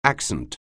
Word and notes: accent: anglais

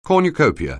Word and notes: cornucopia: anglais

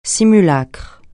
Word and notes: simulacre: francais

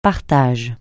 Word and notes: partage: francais

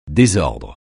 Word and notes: désordre: francais

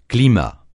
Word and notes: climat: francais

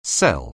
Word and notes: cell: anglais

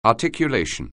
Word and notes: articulation: anglais

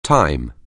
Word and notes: time: anglais

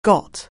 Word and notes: get: anglais

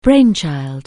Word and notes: brainchild: anglais

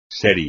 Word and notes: serie: espagnol

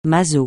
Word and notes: maso: francais

